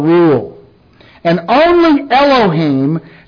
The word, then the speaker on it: Elohim: The first one is Elohim. Pronounced Elohim. Looks like Elohim, but it's pronounced Elohim.